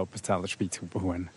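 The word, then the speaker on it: Appenzeller Spitzhaubenhuhn: Jetzt sind wir da beim Restaurant vorbeigelaufen und dann ist uns ein Huhn über den Weg gelaufen, ein Appenzeller Spitzhubenhuhn. Habe ich das richtig gesagt? – Ja, genau, das ist das Appenzeller Spitzhubenhuhn.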